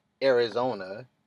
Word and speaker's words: Arizona: Sad note, why do we pronounce it Arizona when it's A-R-I, not A-I-R? Well, it is pronounced Arizona in English. In a dictionary, when it breaks it down to syllables, it is E-R-A-I-Zona. We are pronouncing it right, it's just that it's not spelt the way we pronouncing it.